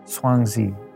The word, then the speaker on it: Zhuangzi: Sometimes it's pronounced Zhuangzi, but in English we've pronounced it Chuangzu or Laozi, the author of the Tao Te Ching.